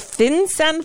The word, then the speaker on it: FinCEN: The FinCEN files? Is that how it's pronounced? The what? – Yes, you've pronounced it correctly.